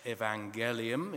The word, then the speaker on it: evangelium: It became a Latin word, evangelium, or evangelium, if you do a hard G for Latin.